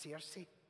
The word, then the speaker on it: Searcy: That's where Searcy is, which should be pronounced Circe there's no you in the word.